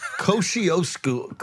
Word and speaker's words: Kosciuszko: Because right now I see the Kajuko Bridge and I don't know if that's close at all to the airport. – Kosciuszko. Kosciuszko. – Wait, what? – Kosciuszko. – I said it wrong, and then you tried to correct me.